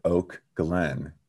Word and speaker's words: Oak Glen: Well, and the thing too is you have to pronounce it Oak Glen. Because most people think I'm saying Oakland, like in Northern California.